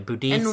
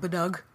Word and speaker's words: Boudica: Because Queen Victoria, like, Boudica's name has been known to be pronounced a number of different ways. Boudicca. – In Welsh, they call it Budug, which is the least prettiest.